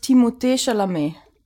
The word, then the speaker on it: Timothée Chalamet: Also, wenn man wirklich Französisch das ausspricht, ist das Timothée Chalamet.